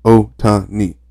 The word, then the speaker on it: Ohtani: Ohtani. Three syllables. Ohtani.